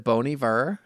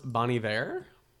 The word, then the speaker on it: Bon Iver: Is it Bon Iver? How do you say it? You love him. – I think it's pronounced Bon Iver. – Bon Iver.